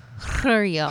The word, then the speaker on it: Jairo: Anyways, J-A-I-R-O, Jario Mora Sandoval. Jario. – It's pronounced Jario.